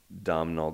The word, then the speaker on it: Domhnall: is I watched About Time with Domhnall Gleeson.